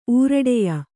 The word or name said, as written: ಊರಡೆಯ